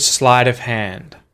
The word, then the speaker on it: sleight of hand: I wonder if you notice what happens to the T at the end of the word slight when you say the phrase sleight of hand. What happens to it? Sleight of hand. I'm not saying sleight of hand. I'm saying sleight of hand. It turns into a T flap.